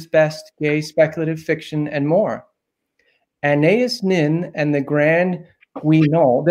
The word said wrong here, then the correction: Guignol: Did I pronounce that correctly? – No, you said wrong. Guignol. – It worked for me. – Guignol. – Guignol. Thank you.